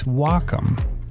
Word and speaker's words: Wacom: No, it's Wacom. Pronounce wa like the Japanese word for harmony and come like computer.